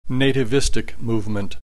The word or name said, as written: nativistic movement